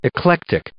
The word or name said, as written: eclectic